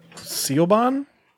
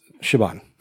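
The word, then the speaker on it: Siobhan: How would you pronounce that name? Sealbon? Shaban.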